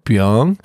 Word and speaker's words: Byung: I was like, do you mean Byung? He's like, oh, yeah. Oh, yeah, that makes more sense. – It makes more sense because that's how you pronounce my name. – My name is spelled B-Y-U-N-G for people that want to know.